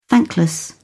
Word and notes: thankless: British English

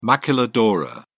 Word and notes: maquiladora: British English

